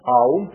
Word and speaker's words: АУМ: Он говорит, этот слог ОМ, он более полно произносится как АУМ. АУМ, три буквы, потом в ОМ превращается.